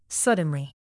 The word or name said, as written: suddenly